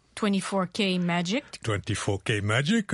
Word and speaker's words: 24K Magic: 24K Magic,